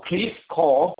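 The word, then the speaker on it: Creve Coeur: So Cliff Call, which is the town name.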